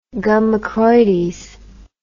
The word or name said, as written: gum accroides